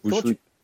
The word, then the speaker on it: Bushwick: Bushwick. – Comment tu... Bushwick à Ça s'écrit euh, B-U-S-H-W-I-C-K.